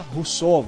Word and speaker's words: Rousseau: Enquanto o homem ameaçava Rousseau, vou falar Rousseau, não sei o nome dele. – É, não, bela pronúncia.